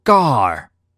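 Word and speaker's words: gar (γάρ): 第七节的第一个字在希腊文当中读作 gar，意思是因为。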